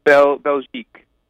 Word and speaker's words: Belgique: So last year, your EP, I believe it pronounced Belkique Bay. Did I say that right? – Belgique. – Belkique, gotcha.